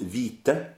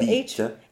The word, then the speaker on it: hvide: Now, it was interesting because in the other song, when you and I talked about it, we didn't pronounce the H when it comes before another consonant. – No. – Does that hold true with all consonants? Yes, it does. Yes, it does. This one is now pronounced vite. You have a D, but that's it's now with a T. – Okay, so vite. H-V-I-D-E.